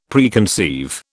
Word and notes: Preconceive: British English

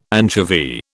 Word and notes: anchovy: British English

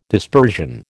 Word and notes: dispersion: American English

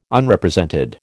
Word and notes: Unrepresented: American English

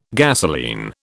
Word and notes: gasolene: British English

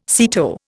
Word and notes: SEATO: Indian

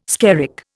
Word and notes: skerrick: Indian

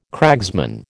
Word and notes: cragsman: American English

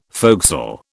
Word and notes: forecastle: British English

